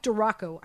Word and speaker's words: DiRocco: Mike DeRocco uh, Rocco, sorry, Mike DiRocco. I keep on pronouncing it wrong.